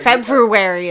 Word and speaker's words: February: But February is not how you say it. – There's no such thing as a silent R. Is there? – Probably. Yeah, in February.